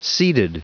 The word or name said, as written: seeded